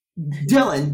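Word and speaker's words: Dillian: So my Instagram name is King, period, or dot, Dylan. That's D-I-L-L-I-A-N. Yes, my name is a little bit hard to spell. It looks like it's spelling Dillion, but the I is silent.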